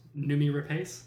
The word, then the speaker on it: Noomi Rapace: Noomi Rapace.